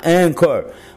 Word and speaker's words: Anchor: que se escreve com CH Anchor, mas se fala Anchor.